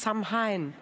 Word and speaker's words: Samhain: Und äh, dann hast du nämlich dieses, fand ich total spannend, dieses irisch-keltische Totenfest Samhain. Ja spricht man das so aus? Ja.